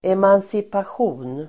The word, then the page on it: emancipation: emansipasj'o:n